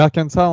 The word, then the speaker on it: Arkansas: Euh, non, Arkansas ou Arkansas Et Kansas ou Kansas On dit comment ah, euh, euh, euh, Arkansas, tu dis Arkansas, Arkansas, j'arrive jamais à le dire. ma, femme se, moque toujours de moi. Et Kansas, c'est Kansas. Mais Arkansas, en général, tu prononces pas le, dernier S d'habitude. Ça, permet de créer une différence, je suppose.